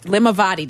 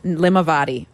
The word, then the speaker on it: Limavady: Limavady. Did I say it right, Eric? – Nailed it. – Limavady.